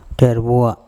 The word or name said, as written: Therboa